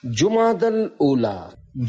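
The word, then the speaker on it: جمادى الأولى: لغت کے اعتبار سے ان دونوں مہینوں کے درست نام اور صحیح تلفظ یہ ہے، جمادہ الاولی، جمادہ الاخری اور جمادہ الاخرہ.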